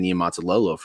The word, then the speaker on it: Niumatalolo: Niumatalolo for a number of years.